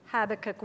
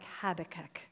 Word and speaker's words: Habakkuk: And true confession, ladies, that's the last time I'm going to say it like Habakkuk, because I am an American, and I tried really hard to shift it in my head, but we say Habakkuk.